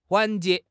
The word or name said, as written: 环节